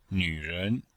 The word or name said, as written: nv3ren2